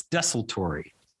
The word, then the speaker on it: desultory: Is that how you say it? I've always said it desultory. – Wrong. – I've always said desultory. – That's how I've always said it. – And I listened to it this morning. It's desultory. – Desultory. Oh my gosh. I'm so glad we're doing words of the episode because I've been saying it wrong for years.